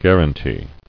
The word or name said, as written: Guaranty